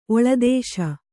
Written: ಒಳದೇಶ

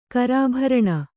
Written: ಕರಾಭರಣ

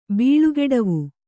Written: ಬೀಳುಗೆಡವು

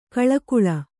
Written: ಕೋಳ್ಗೊಳ್

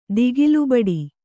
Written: ದಿಗಿಲುಬಡಿ

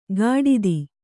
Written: ಗಾಡಿದಿ

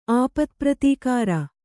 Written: ಆಪತ್ಪ್ರತೀಕಾರ

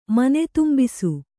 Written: ಮನೆದುಂಬಿಸು

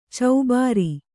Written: ಚೌಭಾರ

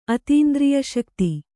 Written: ಅತೀಂದ್ರಿಯಶಕ್ತಿ

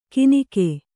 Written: ಕೀನಿಕೆ

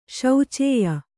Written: ಶೋಚ್ಯ

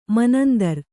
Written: ಮನಂದರ್

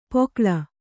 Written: ಪೋಕಳ

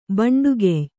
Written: ಭಂಡುಗೆಯ್

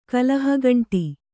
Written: ಕಲಹಗಂಟಿ

